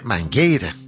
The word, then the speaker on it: Mangueira: Que te decía, ves que es, se escribe Mangueira. Debería pronunciarse Mangueira. Como, pero me explicabas tú que allí la I se la comen directamente. – Sí, los brasileños la comemos ahí.